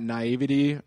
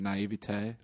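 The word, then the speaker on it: naïveté: Well, you mentioned that naivety, or as I pronounce it, naivete.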